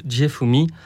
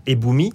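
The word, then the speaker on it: fumi-e: Alors déjà cette pratique, alors c'était une prononciation un petit peu ancienne, aujourd'hui on prononce ça Ebumi.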